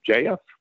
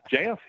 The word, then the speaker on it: Jeff: And he said, JF? Because apparently in the South, my name has two syllables. He said, JF, he said, become a celebrity in your own business.